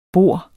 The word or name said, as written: bor